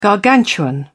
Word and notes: gargantuan: British English

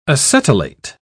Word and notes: acetylate: British English

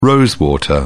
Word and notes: rose water: British English

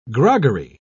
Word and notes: groggery: British English

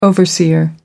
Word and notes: overseer: American English

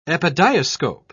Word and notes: epidiascope: British English

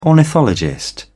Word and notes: ornithologist: British English

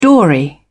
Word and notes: dory: British English

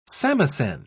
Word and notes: samisen: British English

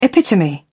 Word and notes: epitome: British English

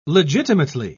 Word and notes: legitimately: British English

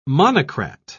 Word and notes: monocrat: British English

